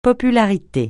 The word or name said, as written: popularité